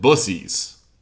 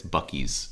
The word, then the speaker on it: Buc-ee's: Yeah, I've always called it Bussies. That's incorrect, though. – It is. No, it's not that. It's Buc-ee's. It's like a very, it's a hard C.